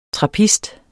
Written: TRAPPISTER

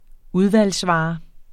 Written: udvalgsvare

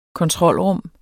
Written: kontrolrum

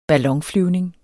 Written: ballonflyvning

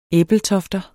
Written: ebeltofter

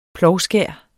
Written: plovskær

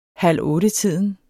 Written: halvottetiden

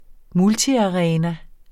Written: multiarena

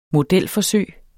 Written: modelforsøg

